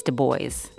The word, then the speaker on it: Du Bois: Du Bois, and Angela Davis. – Sorry to correct you, but I think it's Du Bois, W.E.B. Du Bois. – That's how he pronounced it.